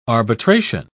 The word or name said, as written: arbitration